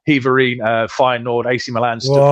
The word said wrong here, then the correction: Heerenveen: Have another go. – Heverine. – There's an N in the middle. – Heerenveen, yeah, whatever.